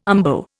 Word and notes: umbo: Indian

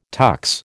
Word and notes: tux: American English

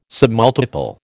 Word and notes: submultiple: American English

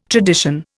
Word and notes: tradition: Indian

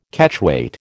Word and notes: Catchweight: American English

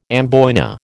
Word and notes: amboyna: American English

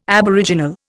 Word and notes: aboriginal: Indian

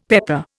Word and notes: pepper: Indian

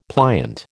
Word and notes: pliant: American English

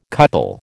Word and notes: couple: American English

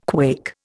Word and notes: quake: Indian

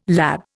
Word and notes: lab: Indian

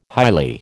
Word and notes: highly: American English

